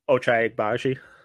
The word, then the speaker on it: Ochai Agbaji: Ochai Agbaji. – Ochai Agbaji.